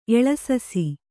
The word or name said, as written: ಎಳೆಸಸಿ